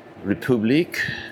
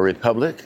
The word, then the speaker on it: République: A 10-minute walk to the train station, and then I'll catch the train and take the ride a few stops to République or Republic, depending on how you say it.